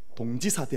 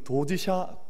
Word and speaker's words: 도지샤: 그리고 졸업과 함께 일본으로 건너가서 1942년에 일본 유학을 떠나서 옛날에 저희가 공부할 때는 다 시인들 공부할 때 동지사 대학 그렇게 불렀었더랬습니다. 왜냐하면 그냥 한문 그대로 불렀기 때문에 요즘은 도지샤 발음 나는 대로 불러서 도지샤 대학이라는 곳에 가서 유학을 했습니다.